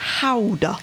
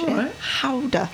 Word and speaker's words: Gouda: It's actually pronounced Gouda in Dutch. Gouda.